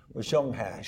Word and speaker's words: Zheng He: 15th century Chinese Admiral Zheng He. – Zhonghai.